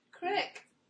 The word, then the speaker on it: creek: How do you say C-R-E-E-K? How do you say C-R-E-E-K? Pronounce it. – Crick. – Crick, there you go. That's where I was from. Crick. I moved out here to Washington, and people out here always called it a creek.